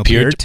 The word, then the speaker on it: Peart: Matt, I'm going to take the opportunity to correct you on your pronunciation of Neil Peart.